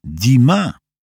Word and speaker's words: Dumas: Właściwie jest francuskie i wymawia się je tak samo jak tego słynnego pisarza, Dumas.